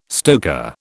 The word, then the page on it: stoker: British English